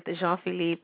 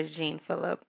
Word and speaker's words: Jean Phillipe: Everybody says it different. You know, I'm okay with Jean Phillipe or Jean Phillipe, you know.